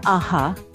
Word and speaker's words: a-ha: And they're called AHA, which was really strange at the time anyway. And it's spelled A-H-A.